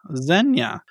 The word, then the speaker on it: Xenia: Our next Reddit user, Xenia? Is that how that's pronounced? – I think you pronounce X's like a Z. Yeah, I'm into it. – Yeah, Xenia.